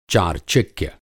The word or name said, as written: चार्चिक्य